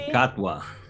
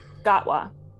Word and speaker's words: Gatwa: Well, how do you say his last? – Shruti Gatwa. – Gatwa. But Gatwa is also a fantastic actor from the one show that I watched him on.